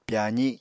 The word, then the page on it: pyazi: Apatani